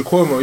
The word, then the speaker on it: Cuomo: Can't pronounce his name. It's Cuomo? Yeah, Andrew Cuomo, yeah.